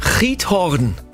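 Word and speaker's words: Giethoorn: Ja, und zwar geht es nach, spricht sich mit R aus, also Riethorn, aber mit G wird's gesprochen, aber die Niederländer sprechen sowas ja immer mit einem R aus. – Riethorn.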